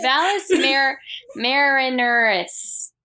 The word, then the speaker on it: Valles Marineris: I don't know how to pronounce that. Marineris. Marinara. Valles Marinara. No, Valles Marineris.